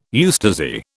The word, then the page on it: eustasy: British English